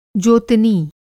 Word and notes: जोतनी: Hindi

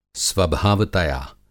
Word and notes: स्वभावतया: Hindi

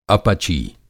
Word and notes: अपची: Hindi

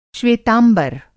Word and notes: श्वेतांबर: Hindi

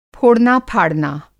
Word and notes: फोड़ना फाड़ना: Hindi

